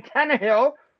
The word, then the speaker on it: Tannehill: but it's Ryan Tannehill, right? Ryan Tannehill. Oh yeah, Tannehill, two and zero, oh. right? Two and zero. Oh, I used to say Tannehill.